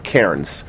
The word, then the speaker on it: cairn: If you ever go hiking, many places you'll find they're called cairns. That's how you pronounce the word, C-A-R-N, yeah.